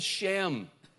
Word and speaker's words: Shem: For example, the name Shem, S-H-E-M, and it's pronounced in the Hebrew, Shem. That's the way it goes. Almost the same, but like our shame, S-H-A-M-E.